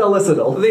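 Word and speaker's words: Elessedil: Poppy Drayton plays Amber Elsadiel. And How do you say it, by the way? Elisadil? I've always said Elisadil. – Yeah, I think that's a good pronunciation of it.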